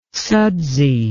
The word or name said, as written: sudsy